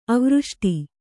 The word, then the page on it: ಅವರಿಷ್ಠ: avariṣṭha